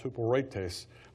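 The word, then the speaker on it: huperetes (ὑπηρέτης): So the word serve here is, if you translate literally, so you have H-Y-P-E-R-E-T-E-S is how it's spelled. It's pronounced huperetes.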